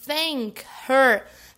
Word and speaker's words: thank: Thank her. Olha só, o TH com a linguinha entre os dentes. Agora, se ao invés de pronunciar desse jeitinho que eu falei, que é o correto,